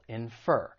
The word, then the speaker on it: infer: The verb, it has two syllables. And once again, the stress is on the second syllable, infer.